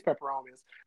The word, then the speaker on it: peperomia: Is it peperomias? That's what peperomia. That's right.